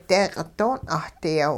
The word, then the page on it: deeqi' don' 'ahdiyaw: hupa